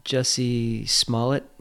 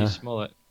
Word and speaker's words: Jussie Smollett: You got the name right, too. – I did, huh? – Jesse Smollett.